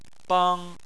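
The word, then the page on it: bang: tone 1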